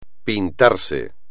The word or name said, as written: pintarse